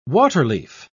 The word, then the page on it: waterleaf: British English